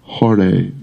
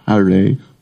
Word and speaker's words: Hare: Hare Krishna. Hare, not Hare. Hare. It's like an O sound.